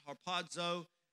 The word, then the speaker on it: harpazo: And you know, we've talked about this many times, but that word caught up actually means, it's a word, you pronounce it harpazo, and it means caught, like you catch something in a snare or a trap, but it means like caught and snatched.